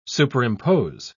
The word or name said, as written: superimpose